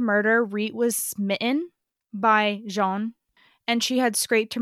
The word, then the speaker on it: Jean: So the number one suspect in Reet's murder is John, or Jean, how it would be pronounced in Montreal.